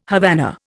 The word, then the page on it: Havana: Indian